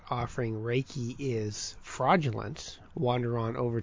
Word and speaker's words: Reiki: I actually always want to pronounce it Reiki, but it's Reiki.